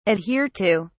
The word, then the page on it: adhere to: British English